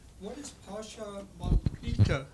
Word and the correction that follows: Prajnaparamita: I think I'm not pronouncing Prajnaparamita? – Right. Yeah, that.